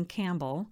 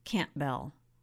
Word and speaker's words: Campbell: Family lore had it that Scott's grandmother didn't want a last name that rhymed with camel. So instead of the usual pronunciation like you'd hear for the music legend Glen Campbell, they said Campbell, like it was two words.